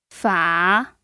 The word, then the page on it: fa: 3